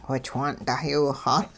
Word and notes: whichwaan' dahyiwixaal: Hupa

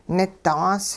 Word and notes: nidaas: hupa

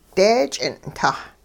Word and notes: deech'ing'-tah: hupa

